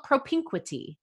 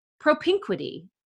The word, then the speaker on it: propinquity: Propinquity, and I'm going to spell it for you. P-R-O-P-I-N-Q-U-I-T-Y.